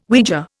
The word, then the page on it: Ouija: Indian